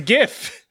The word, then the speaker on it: GIF: It's a gift. – Yeah. It's a beautiful gift. – Sorry. We all learn that it's pronounced GIF.